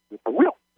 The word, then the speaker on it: whippoorwill: But they don't say it the way I pronounced it. They put the accent on the third syllable, and so it comes out whippoorwill, whippoorwill, whippoorwill.